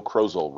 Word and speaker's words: Krozel: Saturday, August slash October 9th by Joe Krozel, rhymes with puzzle.